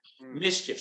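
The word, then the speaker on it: MSCHF: it's spelled funny. It's M-S-C-H-F, Mischief. So if you want to search it online, it's M-S-C-H-F.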